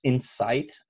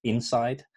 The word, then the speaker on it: Insight: And I just want to stress for the listeners, because I don't know if I pronounce it clearly enough, it's Insight and not Inside. So it's I-N-S-I-G-H-T, right? So Insight that way.